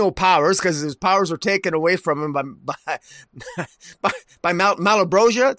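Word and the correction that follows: Malebolgia: Did I say that right? – Malabulgia, yeah.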